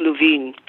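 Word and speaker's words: Lewin: It's L-O-E-W-N. But it's pronounced Luvin.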